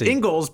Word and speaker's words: Ingles: Pronounced Ingles because they apparently have no respect for other people's culture down in Australia. That last name is clearly Ingles, but they're just like, nah, we're going to call it Ingles because we don't care about your language.